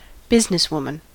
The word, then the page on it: businesswoman: American English